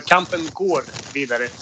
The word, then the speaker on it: Kampen går vidare: Kampen gada vidare. – Yeah, sure, but there's an O in the middle, so Kampen går vidare.